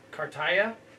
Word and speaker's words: Cartaya: He was going into this season their number two top prospect behind a catcher named Diego, I'm not even going to say it correctly, Cartaya. – Sounds about right.